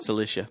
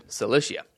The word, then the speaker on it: Cilicia: Afterward, I went to the regions of Syria and Sicilia. Did I say that right? – Cilicia. – Cilicia.